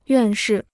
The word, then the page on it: 院士: yuàn shì